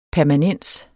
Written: permanens

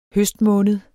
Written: høstmåned

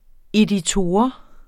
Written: EDITOREN

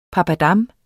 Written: pappadam

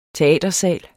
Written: teatersal

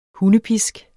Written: hundepisk

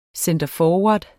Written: centerforward

